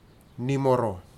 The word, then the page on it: nimoro: futuna aniwa